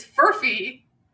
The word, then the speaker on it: Furphy: So the word of the day is furfy. It's not Furby, like the little demonic toys. It's Furby. And it's spelled F-U-R-P-H-Y.